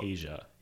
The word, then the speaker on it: Aja: It's pronounced Asia. It's a continent.